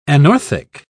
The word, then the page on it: anorthic: British English